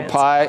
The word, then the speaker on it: pi: Do you know pi is actually pronounced P? – What? – That doesn't work either. Yeah, in classic Greek.